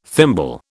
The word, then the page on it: thimble: British English